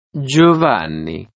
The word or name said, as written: giovanni